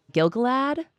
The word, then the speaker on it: Gil-galad: They were built in Second Age 600, and they were built by Gilgalad.